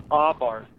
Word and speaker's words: Abarth: An expert told me how to pronounce it. It's called Abarth.